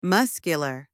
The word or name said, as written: muscular